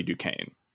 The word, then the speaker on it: Duquesne: And you know that my name is Buddy Duquesne. – And my name is Alice White. – So that means that my Twitter handle is Buddy _ Duquesne. And Duquesne is spelled D-U-Q-U-E-S-N-E.